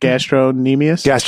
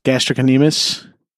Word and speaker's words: gastrocnemius: Wait, how do you say that? Gastronemius? – Gastrocnemius.